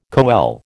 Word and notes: coele: American English

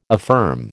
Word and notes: affirm: American English

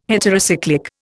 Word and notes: heterocyclic: Indian

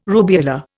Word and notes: rubeola: Indian

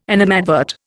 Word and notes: animadvert: Indian

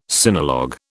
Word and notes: sinologue: British English

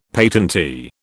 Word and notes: patentee: British English

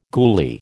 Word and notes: goolie: American English

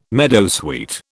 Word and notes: meadowsweet: British English

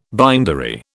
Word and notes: bindery: British English

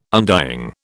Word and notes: undying: British English

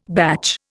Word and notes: batch: Indian

